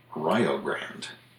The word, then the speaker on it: Rio Grande: But there is a town in Ohio where they pronounce the name Rio Grande.